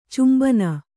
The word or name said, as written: ಚುಂಬನ